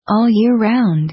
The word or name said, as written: all year round